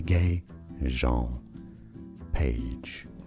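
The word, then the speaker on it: Régé-Jean Page: Reggae Jean Page. – Reggae? – So it is. – His name is Reggae? – Reggae, bro. – Reggae Jean Page.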